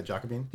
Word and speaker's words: Jacobin: It's just like how do you say that name? The Giacobbean? The one that you brought us last time. – Jacobin. – Yeah, Jacobin.